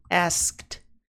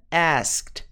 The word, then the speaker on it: asked: Ela perguntou, she, ela perguntou, passado, asked. Pronúncia, asked, asked, asked.